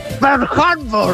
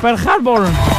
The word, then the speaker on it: Pearl Harbor: Se pronuncia así. Pearl Harbor. – Eso. Pearl Harbor.